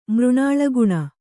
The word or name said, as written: ಮೃಣಾಳಗುಣ